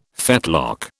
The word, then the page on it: fetlock: British English